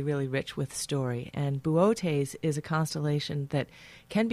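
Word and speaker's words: Boötes: Bootes.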